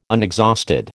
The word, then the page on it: unexhausted: American English